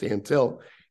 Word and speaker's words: Antil: And my last name is pronounced Antil. And he's like, comes walking in. There's 50, 60 people in this little room up there because Bobby Cox was going to give a press conference for the team. And he goes, hey, where's Ann Hill? Pronounce my name like Ann Hill.